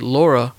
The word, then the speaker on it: Lara: Laura is Laura Croft, and it's spelled L-A-R-A, but you say Laura because she's British.